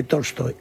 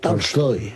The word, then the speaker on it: Tolstoy: No es exactamente Tolstoy, es Tolstoy. Tolstoy.